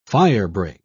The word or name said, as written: firebreak